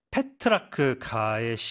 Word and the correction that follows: 페트라르카: 그렇죠? 페트라르카. 발음이 어렵네요.